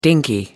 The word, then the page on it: dinky: American English